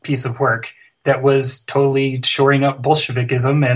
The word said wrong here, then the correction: Bolshevism: and that's not how you say it, Bolshevism.